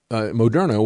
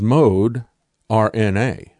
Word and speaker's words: Moderna: And the original Moderna was originally pronounced as mode RNA, mode RNA, and then they changed it to Moderna.